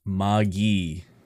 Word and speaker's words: Magi: It is a hard G and a short I. – What? – It's pronounced Magi.